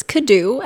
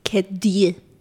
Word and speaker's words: Cadieux: If you want to say it in English, it's Cadieux, and if you want to say it in French, it's Cadieux.